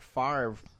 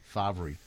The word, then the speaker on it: Favre: Favre. – It should be Favre, right. But no, the R can be anywhere. We'll just pronounce it this way.